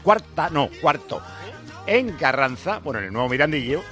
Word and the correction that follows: Mirandilla: Jolín, ¿cómo estoy? Mirandilla. Cádiz Rayo Vallecano, eso lo he dicho bien.